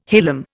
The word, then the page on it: hilum: Indian